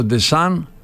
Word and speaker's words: Deschamps: Και ο νυν προπονητής ο Ντεσάν, παρότι γράφεται με MP αλλά προφέρεται με Ni De San, έχει 103.